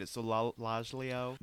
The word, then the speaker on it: Solgaleo: That's how you say it. Solgaleo.